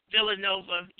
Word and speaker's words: Villanova: Villanova University.